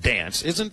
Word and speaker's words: DNCE: By DNCE, okay. Which I guess would be pronounced dance.